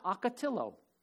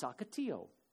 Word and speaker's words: ocotillo: It's not ocotillo, it's ocotillo.